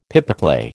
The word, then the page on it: pipeclay: American English